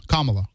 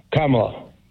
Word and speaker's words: Kamala: Kamala. Kamala and then L.A. Kamala. – Kamala.